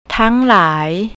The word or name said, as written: tanglai